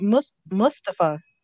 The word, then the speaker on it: Mustafa: Mustafa? How you say it? – It sounds like Mustafa. – Mustafa. – So yeah. I said it right.